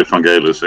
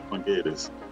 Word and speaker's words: Vangelis: I was inspired by guys like Jean-Michel Jarre and Vangelis, or Vangelis, you should say Vangelis, actually. – is that your reason to pronounce it? – Yeah, I think that's the right Greek pronunciation, I think, yeah, Vangelis.